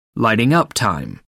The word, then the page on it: lighting up time: American English